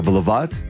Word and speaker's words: баловать: Будут тебя баловать. Такое а-ля дворянское. – Так. – Вот. Это неправильно. Правильно теперь говорить «баловать» и «баловаться».